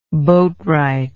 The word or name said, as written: boatwright